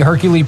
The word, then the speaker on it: Hercule: Hercules? – Hercule. I think that's how you pronounce it.